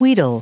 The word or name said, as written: wheedle